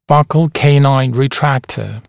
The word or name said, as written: buccal canine retractor